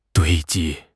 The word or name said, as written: 堆积